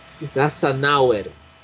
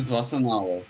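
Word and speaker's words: Szafnauer: É, a pronúncia correta do Otmar é Svassan, Svassanauer. Ah, o chefe da Alpine, Otmar. Otmar Svassanauer. Svassanauer.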